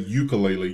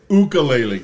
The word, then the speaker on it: ukulele: Well, now, hold on for just a second, because I always have said ukulele. You're saying... – Ukulele. – Ukulele. – Yeah. – Is that the proper... – Yeah, that's the proper way to say it, ukulele.